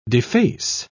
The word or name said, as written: deface